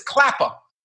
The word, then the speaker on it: Klapper: How do you spell that? I said, it's Klapper. She goes, okay, I got to write it on the board for the nurses to call you. So she writes K-L-A-P-P-A-H. Klapper.